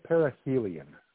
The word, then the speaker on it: perihelion: That's going to survive its perennial. I said that right. – Yeah, perihelion.